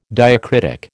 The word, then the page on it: diacritic: American English